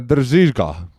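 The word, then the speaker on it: Drzyzga: Renksiga, Renksiga Need probleemid ei lahene ka Need probleemid ei lahene ka muidugi Äh, läheme edasi, äh, meeskonna sidemängija Fabian äh, Držižka on õige hääldus, siis nii nagu juba Reneelt oleme õppinud.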